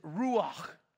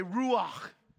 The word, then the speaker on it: ruach: Okay, in the Hebrew, the word for spirit is ruach. Now everybody say ruach. Okay, if you're not spitting, you're not saying it right. It's glottal. It actually comes from deep in the throat.